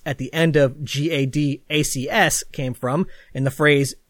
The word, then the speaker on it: GADACS: Next was an experiment with an acronym that I'm going to pronounce as GADACS. That's G-A-D-A-C-S.